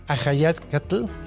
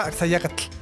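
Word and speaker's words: Axayácatl: Es que no, creo que sí dice Ajayacatl. Yo siempre le aviso Axayacatl, pero... – En la escuela la maestra me lo pronunciaba Axayacatl. – Pero es que, sí, según tengo entendido, es Ajayacatl.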